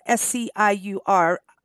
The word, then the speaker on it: Sciuridae: Their family of animals is called, and hopefully I can pronounce this, Sciuridae. It is Sciuridae.